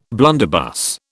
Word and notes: blunderbuss: British English

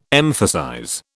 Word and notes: emphasize: British English

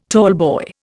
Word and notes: tallboy: Indian

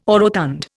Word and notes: orotund: Indian

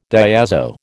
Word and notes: diazo: American English

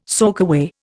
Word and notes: soakaway: Indian

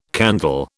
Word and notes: candle: British English

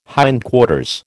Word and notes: hindquarters: American English

